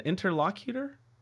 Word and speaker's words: interlocutor: How does that word, is that the right pronunciation, interlocutor? – I think you pronounced it right, but I don't think normal people know what it means.